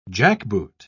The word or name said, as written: jackboot